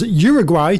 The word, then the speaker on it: Uruguay: Sorry. Uruguay. Thank you for correcting my pronunciation.